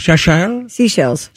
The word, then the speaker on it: Seychelles: Seychelles. – Seychelles? No, it's S-E-Y-C-H-E-L-L. – I know, but they call it seashells.